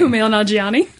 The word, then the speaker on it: Kumail Nanjiani: The film stars Kumani Nanjiani as am I saying that right? – Kumail Nanjiani. Kumail Nanjiani. – Take a drink, everybody. – There you go. – I'm just going to... It's Kumail? – Kumail. – Fuck. Kumail.